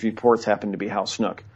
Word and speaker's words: Snook: And the other person is a Napa County deputy sheriff named Hal Snook, you could pronounce it Snook. It's spelled S-N-O-O-K. As far as I know, the correct pronunciation is Snook.